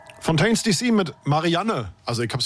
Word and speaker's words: Fontaines DC: Und du hast es wirklich falsch ausgesprochen, weil es ist Fontaines DC.